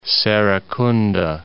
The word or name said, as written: Serekunda